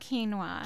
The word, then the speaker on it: quinoa: It's called quinoa. – Quinoa, but it's spelled strangely. What's the spelling on it? – It's spelled Q-U-I.